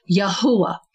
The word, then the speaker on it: Yahuwah: And that is the correct pronunciation of the God of Israel, which is Yahuwah. So, you know, people call him Yahweh just because of, you know, language and how language got watered down. Jehovah